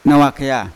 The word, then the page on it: nawa kea: Yawa